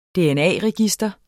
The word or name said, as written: dna-register